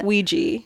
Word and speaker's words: Ouija: Ouija.